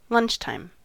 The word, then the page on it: lunchtime: American English